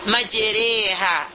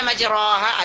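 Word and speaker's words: majereha (مجراها): majereha, majereha. Itu kita membacanya majereha. Tak boleh kita baca majeroha. Ada yang baca majeroha. Tak boleh kita baca majriha.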